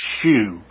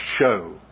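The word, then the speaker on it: shew: It isn't, don't pronounce it, shoe. I know you think it rhymes with, like, the word hue, but it isn't shoe, the word is show, the way you pronounce it.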